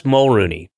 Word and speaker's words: Mulroney: Wait, how do you pronounce his last name? – It was Mulroney. Dermot Mulroney.